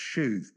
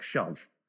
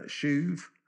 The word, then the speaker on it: shuv: The second Hebrew word is I think it's pronounced shuv, but it reads like shove.